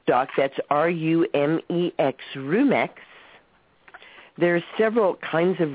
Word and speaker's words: Rumex: R-U-M, like rum, E-X, like a has-been. – Oh, Rumex. – Okay. – R-U-M-E-X.